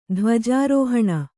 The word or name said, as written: ಧ್ವಜಾರೋಹಣ